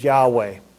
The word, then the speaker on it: Yahweh: Now, no one today knows for certain how to pronounce this name because the vowels were left out, and they could put any vowels that they want in there. So nobody knows exactly how, but the most common pronunciation is Yahweh. But the common, although errant, mistranslation is Jehovah.